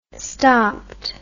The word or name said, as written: stopped